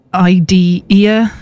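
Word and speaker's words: Idear: It's I-D-E-A-R. So you could shorten it to Idea if you want to, but it's pronounced Idea, I'm sure.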